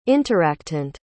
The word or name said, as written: interactant